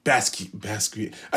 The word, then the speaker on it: Basquiat: I got some, you know, Basquiat. I'm pronouncing that right, right? Yeah. Basquiat.